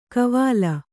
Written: ಕವಾಲ

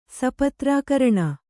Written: ಸಪತ್ರಾಕರಣ

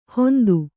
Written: ಹೊಂತು

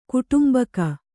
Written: ಕುಟುಂಬಿಗ

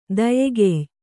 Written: ದಯಗೆಯ್

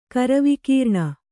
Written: ಕರವಿಕೀರ್ಣ

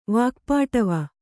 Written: ವಾಕ್ಪಾಟವ

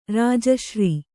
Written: ರಾಜಶ್ರೀ

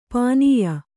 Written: ಪುನ್ಯೆ

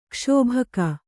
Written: ಕ್ಷೋಭಕ

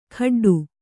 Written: ಕಾಡಾಟು

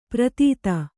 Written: ಪ್ರಥಿತ

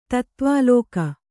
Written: ತತ್ವಾಲೋಕ

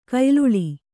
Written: ಕೊಯ್ಲಾಳಿ

